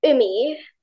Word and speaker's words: Umi: Ummy, but her name is actually pronounced Umi. It's a Japanese name, I think.